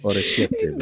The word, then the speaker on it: orecchiette: It's pronounced orecchiette.